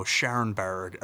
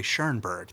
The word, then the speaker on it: Schoenberg: And it was, you know, for us talking about, you know, Schoenberg, or mm-hmm. if I could say that correctly, Schoenberg. There we go. I was trying to say it too fancy.